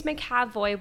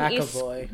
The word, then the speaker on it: McAvoy: So it's going to be described as James McAvoy will not be given McAvoy. – McAvoy, whatever.